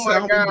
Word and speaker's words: salmon: But the worst part, they were pronouncing the L. – This needs to be rinsed out first. Like, if you were working at a restaurant, you like switch the cup to rinse out the cup. – But salmon. I think Brandy put a post up like that. Like, if they pronounce the L in salmon.